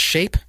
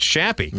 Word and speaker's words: Shappy: It's not pronounced Shape? – No, it's Shappy. Mm. S-H-A... – Because that should be the name of the club.